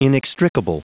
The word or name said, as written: inextricable